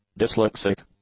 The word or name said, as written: dyslexic